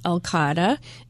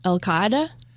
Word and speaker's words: Al-Qaeda: So almost the entire time we were in the agency, we were dealing with Al-Qaeda and multiple tours. – How do you pronounce it? – Al-Qaeda. – I love the fact I've got an actual Arabist in the studio. Say it again. – Al-Qaeda.